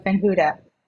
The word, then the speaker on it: Ben-Hutta: I'm not going to get into the Hebrew pronunciation, but Ben-Hutta. – Ben-Hutta. – Ben-Hutta, okay. – Is good enough, yeah.